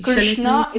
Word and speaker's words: Krishna: Krishna is the correct pronunciation.